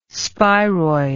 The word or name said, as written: spiroid